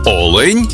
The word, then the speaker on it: олень: в однині, і в множині наголос падає на перший склад. Олень та олені.